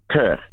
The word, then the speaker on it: Kirk: Kurt.